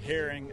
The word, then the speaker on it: Herring: Clint, C-L-I-N-T, Herring, H-E-R-R-I-N-G.